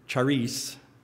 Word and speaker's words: charis: And it is spelled in English, C-H-A-R-I-S. Charis is the way it's pronounced, charis.